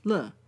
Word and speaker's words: 了: That character, the last one in that previous phrase, is also used as an expletive, pronounced le.